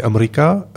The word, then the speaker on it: Amrika: Et donc le film de cet après-midi, ou du début de soirée, Amrika, c'est la prononciation populaire en hindi de America, donc l'Amérique, les États-Unis en particulier.